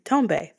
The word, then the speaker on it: Tombe: But Tombe, yes, Tombe. That is how you say it. It's not Tomb. It's not, I don't know what else people have called me. Tombe, think French. If you're a dancer, you probably know. Tombe Padabure, that is how you say my last name.